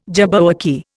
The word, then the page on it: jabberwocky: Indian